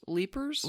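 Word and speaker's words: Leiper's: Leapers? – Leapers. – Like L-E-A-P-E-R. – L-E-I-P-E-R apostrophe S, but it is pronounced Leapers.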